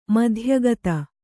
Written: ಮಿಥೋಯುಕ್ತ

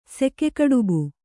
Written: ಸೆಕೆಕಡುಬು

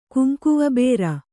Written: ಕುಂಕುವಬೇರ

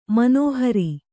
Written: ಮನೋಹರ

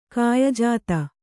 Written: ಕಾಯಜಾತ

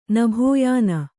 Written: ನಭೋಯಾನ

